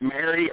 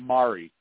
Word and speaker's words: Mari: Um, and my gosh, has there ever been anybody on there with as big a following as Did does she pronounce it Mary or Marie? The second boot, Marie.